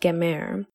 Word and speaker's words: gemer: Okay, and in the Hebrew it is pronounced gemer, and it means to complete.